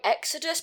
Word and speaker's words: Exodus: So for our next story, it turns out that Metro, I'm going to pronounce this wrong, Exodus, please say that's right. – Yes. – Yes, that's right.